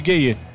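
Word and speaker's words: Gueye: Por sinal, os gayê da seleção de Senegal, na real, a pronúncia correta é gay. É o Papi Gay. Sim. E o Gana Gay. Mas mundialmente eles viraram os gayê.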